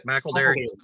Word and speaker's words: McElderry: So that means Tennessee has lost two offensive line commits potentially and um, McElderry. How, how do you say McElderry? McElderry. McElderry.